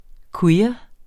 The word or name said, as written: queer